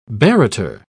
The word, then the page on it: barrater: British English